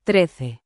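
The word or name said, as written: trece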